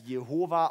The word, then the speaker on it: Jehova: Manche sprechen es als Jahwe, manche als Jehova aus. Man weiß halt nicht genau, wie man es aussprechen soll, weil der Name ist so heilig, dass man ihn nicht aussprechen durfte.